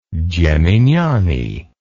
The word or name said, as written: geminiani